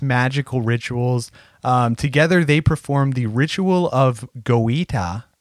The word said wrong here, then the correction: Goetia: I think I'm saying that right. This refers to an evocation of Goetia.